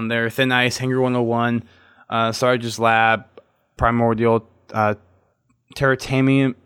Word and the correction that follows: Termitarium: or I can never pronounce that one. Termitarium.